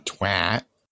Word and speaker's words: twat: The easiest way to tell the difference between a Brit and a Yank is how they pronounce a word spelt T-W-A-T. – The twat. – Yeah, well, British people call it a twat. But for reasons that I'm yet to figure out, although it's spelt T-W-A-T, Americans pronounce it T-W-O-T.